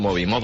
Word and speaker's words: Moby: A mí me gusta mucho Moby, dicen los...